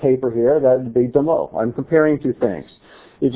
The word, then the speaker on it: damah: It's the word demo. It's a three-letter word. You'd spell it in English D-A-M-A-H. In Hebrew, it's dalet mem he.